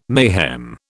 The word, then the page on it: mayhem: British English